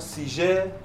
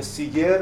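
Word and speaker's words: Siger: سیگریوس یا همون سیژه یا سیگر همه اینا می‌بینید دیگه پس که تلفظ فرانسه باشه یا لاتین باشه یا انگلیسی سیگریوس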